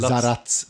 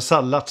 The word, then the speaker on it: Sallacz: Nej, det, tydligen så är det så att den här tyska maskinerna då hette, eh, stavas S-A-L-L-A-C-Z. – Uttalas tydligen Zaratz ja, på, det, på tyska. Ja, men, ja, just det, men Sallat som man läser bara ja, exakt.